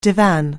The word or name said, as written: divan